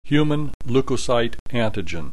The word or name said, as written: human leukocyte antigen